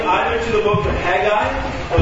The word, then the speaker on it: Haggai: And I prefer to pronounce it Haggai.